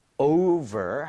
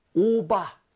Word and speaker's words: over: 자, 여러분들 over 할 때도 입술이 붙으면 안 됩니다. – 네. – V가 있죠? 그래서 오바. 절대 안 돼요. 아랫입술 위에 윗니 살짝 올리고 발음 뿜을게요.